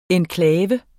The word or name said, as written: enklave